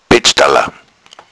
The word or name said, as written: bettstalla